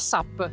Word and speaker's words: SUP: SUP o SAP? – Allora, c'è chi dice SAP, comunque deriva dall'acronimo Stand Up Paddle, americano, quindi...